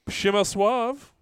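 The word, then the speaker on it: Przemysław: Shimasuav. Yes, that's the pronunciation.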